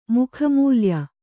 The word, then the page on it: ಮುಖಮೂಲ್ಯ: Kannada